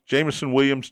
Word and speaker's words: Jameson Williams: Jameson Williams, Detroit got uh, suspended correct.